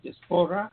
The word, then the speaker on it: diaspora: Or diaspora. – Diaspora. – Diaspora. Okay, the different pronunciation.